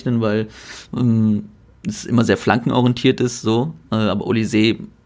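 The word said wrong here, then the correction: Olise: oder Ulis heißt er ja, wird ausgesprochen, der macht es ja auch nicht anders, sondern er dribbelt halt einfach nur die ganze Zeit.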